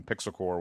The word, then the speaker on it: Pixel Corps: Pixel Corps will, you know, which is spelled, by the way, CORPS. It's like Marine Corps or Peace Corps.